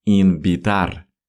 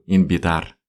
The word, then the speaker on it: invitar: Инбитар, который пишется через букву УВ. Инвитар, но, как всегда, больше мы уходим в звук Б. Инбитар.